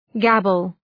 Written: gabble

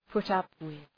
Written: put up with

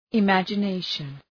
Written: imagination

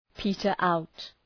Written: peter out

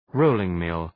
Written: rolling-mill

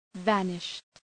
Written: vanished

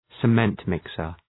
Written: cement mixer

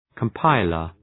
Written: compiler

